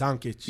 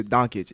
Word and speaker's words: Doncic: Yes, Doncic. – Yeah, Doncic.